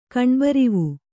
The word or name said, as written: ಕಣ್ಬರಿವು